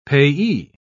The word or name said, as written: payee